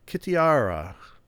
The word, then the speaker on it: Kitiara: Kitiara. The dark-hearted Kitiara. Tiara. K-I in front of it.